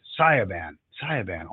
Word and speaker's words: Sayaban: Sayaban, almost like Autobahn.